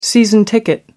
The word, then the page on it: season ticket: American English